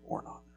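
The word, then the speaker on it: Ornan: I know it's not spelled like Ornon, but it is Ornon, the Jebusite.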